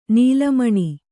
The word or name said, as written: ನಿಲ್ಲುಮನೆ